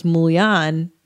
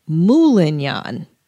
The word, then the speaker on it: moulignan: I thought it was Mulian, but he says Moulignan. Mm-hmm. He adds a syllable to it.